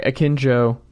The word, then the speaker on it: Akinjo: Let's move forward with our guy Akinjo. That's how you pronounce his last name, right? – Yes.